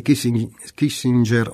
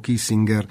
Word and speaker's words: Kissinger: E Kissinger o Kissinger dice agli europei, il, a me piace più la pronuncia tedesca, non lo so perché, ma vabbè, il Cremlino non ha una politica di conquista ed esorta la cautela con Trump, guardare alla sostanza, alla sostanza di Trump.